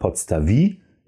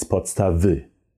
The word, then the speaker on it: podstawy: So it's important to have this I sound at the end of the word. So it's not podstawi, it's podstawy. Podstawy.